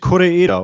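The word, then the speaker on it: Kore-eda: Ähm, ein Film von Hirokazu Kore, es spricht sich Koreeda, oder? Ja, ich sag ja.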